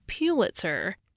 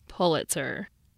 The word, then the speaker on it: Pulitzer: I pronounced the name Pulitzer, and he gently explained the preferred pronunciation to me. The Pulitzer Board pronounces it Pulitzer, which is the way Joseph Pulitzer said it and liked it.